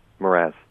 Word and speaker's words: Mraz: Mraz.